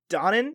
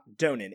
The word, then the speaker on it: Donen: It was directed by Stanley Donen. I think it's Donen, not Donen. It's spelled Donen, but I believe it's Donen. I think that's how Ben Mankiewicz told me to pronounce it on TCM.